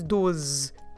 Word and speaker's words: doze: And you might think that we are saying 12, because the word for 12 is doze, and you have the same Z sound at the end, the same Z.